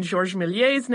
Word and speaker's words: Méliès: I did have to laugh, though, when someone had emailed us and asked why we did not pronounce the R in Georges Méliès's name, but we did pronounce the R in the Lumière brothers' name.